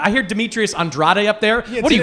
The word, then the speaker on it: Andrade: No, I'm Cape Verdean, and of course my culture, my ethnicity, my background, they pronounce it Andrade.